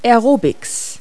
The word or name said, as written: aerobics